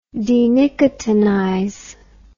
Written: denicotinize

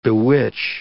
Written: bewitch

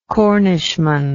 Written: Cornishman

